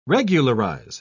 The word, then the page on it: regularise: British English